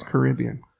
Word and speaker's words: Caribbean: Okay, so has to rhyme with amphibian. Therefore, it's Caribbean. Because I'm a Caribbean amphibian.